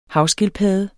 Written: havskildpadde

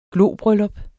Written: globryllup

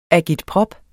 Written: agitprop

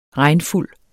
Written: regnfuld

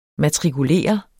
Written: matrikulere

